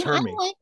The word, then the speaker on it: Hermie: Is that how you say his name? – Yes, Hermie. – I liked that he really was very strong with his passion for dentistry.